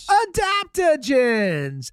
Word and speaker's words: adaptogens: A combination of 75 high-quality vitamins, minerals, whole food source, superfoods, probiotics, and how do you say it? – Adaptogens.